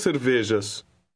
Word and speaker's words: cervejas: Portuguese J is pronounced like the middle of the English word measure. Try number 2. – Duas cervejas.